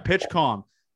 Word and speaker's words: PitchCom: Is that how you say it? PitchCom? PitchCom? – PitchCom, PitchCom. – Yeah, PitchCom.